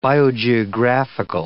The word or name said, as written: biogeographical